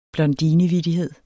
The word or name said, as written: blondinevittighed